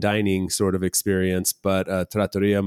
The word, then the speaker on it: trattoria: So trattoria also I always pronounce that in my head, trattoria, it's trattoria. Okay. Epic fail there.